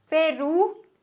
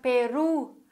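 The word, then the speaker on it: Perú: Sprich, Peru, haben wir ein Wort, das auf Vokal endet. Okay, Wort endet auf Vokal. Das heißt, wir müssen die vorletzte Silbe betonen. Okay, das würde aber bedeuten, dass wir Peru sagen.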